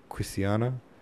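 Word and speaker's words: Quisisana: Above the house door in ornamental gilt lettering was the word Quisiana, by which name the retired little dwelling was known throughout the town, where they pronounced it with a very soft S and a very broad A.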